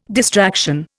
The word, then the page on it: distraction: Indian